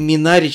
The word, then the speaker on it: Минари: Кстати, хочу вам напомнить, что все-таки Минари читается, ребят, с а, ударением на второй слог.